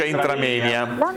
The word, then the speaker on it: intramoenia: Allora è aumentata l'intramenia, si scrive intramoenia e si pronuncia intramenia, significa tra i muri.